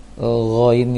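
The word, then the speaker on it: Gho (غ): Ghoin itu bukan huruf go, bukan seperti G-nya bahasa Indonesia. Akan tetapi ada bunyi angin di tenggorokan. Gho, gho.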